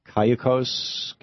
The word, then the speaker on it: Cayucos: But that said, John, in Cayucos, California, am I saying it right, John? – Yes, you are, Tom.